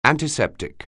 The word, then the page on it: antiseptic: anglais